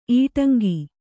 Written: ಇಟ್ಟಂಗಿ

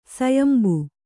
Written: ಸಯಂಬು

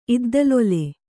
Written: ಇದ್ದಲೊಲೆ

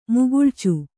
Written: ಮುಗುಳ್ಚು

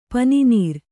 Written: ಪನಿನೀರು

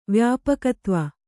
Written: ವ್ಯಾಪಕತ್ವ

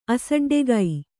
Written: ಅಸಡ್ಡೆಗೈ

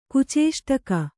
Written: ಕುಚೇಷ್ಟಕ

